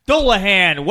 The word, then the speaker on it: Dullahan: Dullahan. Way to pronounce that. I think you hit it right at the Derby, too, when you said Dullum or something.